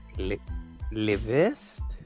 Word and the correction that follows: revés: Ew, no, you're not saying it right. It's revés. – You revés? What's it supposed to be? What? – Revest. – Re- Revest, but roll your R at the beginning.